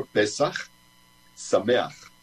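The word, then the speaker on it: Pesach, Sameach (פסח שמח): Pesach, Sameach.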